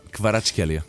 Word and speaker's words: Kvaratskhelia: É o Kvaratskhelia. – Kvaratskhelia. – Repara, é uma grande responsabilidade. Estás a dizer o nome de alguém e não sabes, estás a pronunciar como deve ser.